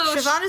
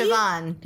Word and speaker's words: Siobhan: Also, Siobhan is one of those great names that doesn't look like how it's spelled. – I literally, every time I see it, have to write phonetically. – Siobhan, yeah.